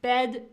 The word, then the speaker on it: bed: So those are pronounced differently. – Bed is what I sleep in. Bed is what I sleep in. And bad is when I'm doing something bad or I feel bad. So these two are actually pronounced differently.